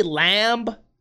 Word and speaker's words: lamb: Maybe lamb is involved. Does anyone else pronounce the B in lamb? Lamb? I say lamb so that people understand what I'm saying. Maybe I should say lamb.